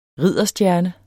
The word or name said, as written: ridderstjerne